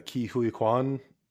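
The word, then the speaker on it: Ke Huy Quan: And then we had Ki Hu Kwan. Is that how we actually say Short Round's name? – Ki Hui Kwan, because I think Chinese friend pronounced it like that the other day.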